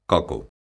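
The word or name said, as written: coco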